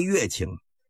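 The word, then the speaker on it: 乐清: 咱书要简言，单说戚继光率领着军队兼程前进，抵达这个温州北面的洛庆，这个地方也叫乐庆，就是音乐的乐，这是个多音字。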